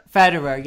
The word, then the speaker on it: Federer: In the book, I don't know how to pronounce that. How do you pronounce? How is it? – Federer, yes, that one.